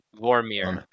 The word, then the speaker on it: Vormir: it, Morvir? I'm butchering that name now. I got Vormir.